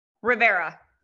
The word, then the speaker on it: Rivera: Rivera. – Rivera. – Well, okay. It's Spanish. It's Latin. So it technically would be Rivera, but I am super white, so I don't say it that way.